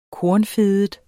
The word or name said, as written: kornfedet